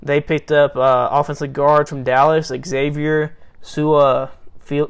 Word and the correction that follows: Su'a-Filo: Feel uh, uh, I butchered his name. Uh, to a three-year deal. Xavier Su'a-Filo. – Yeah. Su'a-Filo. Yeah.